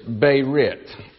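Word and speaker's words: berit (ברית): And the Hebrew word for covenant is berit. You'd spell it B-E-R-I-T.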